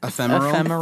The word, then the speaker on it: ephemeral: How do you say that word? – Ephemeral. – Ephemeral. I always want to put another R in there.